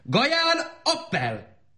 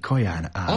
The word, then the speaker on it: Kaján Ábel: Gaján Appel! – Azt akarja mondani, Kaján Ábel. – Appel, Abel. – Csak hogy rendkívül liftói kiejtéssel beszéli Appel a magyart.